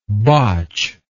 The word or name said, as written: botchedly